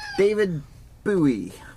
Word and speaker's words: David Bowie: David Bowie. – Yes. Pronounced just like that, too.